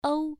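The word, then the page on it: u: tone 1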